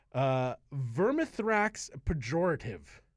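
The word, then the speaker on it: Vermithrax Perjorative: Vermithrax Pejorative.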